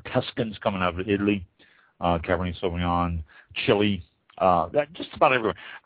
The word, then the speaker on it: Cabernet: Cabernet, the T is silent, C-A-B-E-R-N-E-T, Sauvignon, S-A-U-V-I-G-N-O-N.